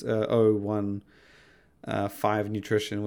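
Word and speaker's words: O15 Nutrition: 5 Nutrition, which you may think 15, it's intended to be pronounced 015 Nutrition, even though it's written as the letter O, the numbers 15, and you may just think 15. It's they made it clear it's 015 Nutrition, and yeah,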